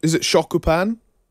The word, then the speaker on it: shokupan: Is it shokupan? – Shokupan, yeah.